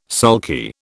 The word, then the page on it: sulky: British English